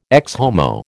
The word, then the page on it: Ecce Homo: American English